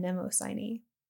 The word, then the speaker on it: Mnemosyne: So about memory, first I want to share just like a broad kind of mythic perspective, because there's this goddess Mnemosyne, and that's spelled, Let me look it up again. It is M-N-E-M-O-S-Y-N-E, Mnemosyne.